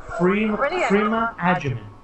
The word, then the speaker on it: Agyeman: From her words, from Agyeman. Her mouth herself. So I think, if I'm not mistaken, I think I've been pronouncing it right all this time.